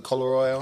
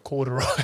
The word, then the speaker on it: corduroy: Got the Colleroy on. Colleroy? Colleroy. – Corduroy.